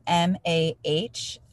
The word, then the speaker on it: Mahler: And my last name is spelled Mahler.